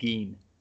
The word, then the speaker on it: Gein: The name is spelled Ed Gein. So it's Gein, which is, why would you fucking name a character after a people eater?